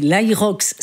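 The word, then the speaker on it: HYROX: Ça s'écrit H-Y-R-O-X. C'est l'aérox.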